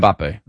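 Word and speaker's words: Mbappé: Mbappe, am I saying that right? – Yeah, Kylian Mbappe.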